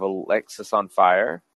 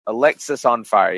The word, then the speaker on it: Alexisonfire: The reemergence, I would say, of Alexis on Fire. Alexis on Fire. Yeah, I said it right the first time.